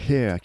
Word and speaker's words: H31R: Oui, Hair, qui s'écrit H31R mais qui se prononce Hair,